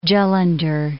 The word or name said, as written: Jalandhar